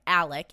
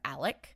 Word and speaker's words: Alex: But his son, Richard Alexander Murdaugh, who goes by Alec, even though it's spelled Alex, he goes by Alec.